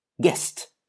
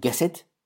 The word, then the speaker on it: guessed: Guessed. Not guess it, but guessed. Straight through. Let it flow through. This does not finish in a D or a T sound.